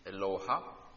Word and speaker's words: Eloha: Det uttalas precis så. Eloha. Inte Eloha eller Eloha, utan Eloha.